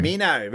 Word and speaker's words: Minto: Mino, we call it, repronounce it, Mino.